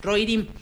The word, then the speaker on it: Rohirrim: Se pronuncia así, Rohirrim.